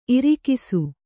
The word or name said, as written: ಇರಿಕಿಸು